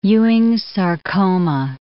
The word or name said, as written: Ewing's sarcoma